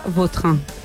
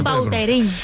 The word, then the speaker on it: Vautrin: La original francesa, Vautrin. – Vautrin. Ahí pega un Bauterín.